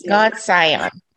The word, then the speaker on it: Godsian: Is it Godsian? – Godsian.